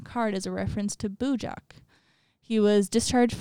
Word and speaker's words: Bujok: Bujok? It's B-U-J-O-K.